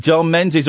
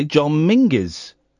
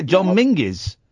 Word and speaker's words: John Menzies: John Menzies, or is pronounced properly John Mingus. – Is he? – Apparently so. I don't buy it for 1 second. But yeah, John Mingus.